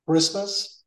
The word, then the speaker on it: Christmas: But yes, here it's CH, but it's K. And the A here sounds like an Christmas. So not Christmas. It sounds like this. Something like this.